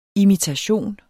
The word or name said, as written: imitation